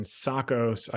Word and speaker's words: Tsakos: So we got Scorpio Tankers, STNG, and Zacos. I believe the T is silent.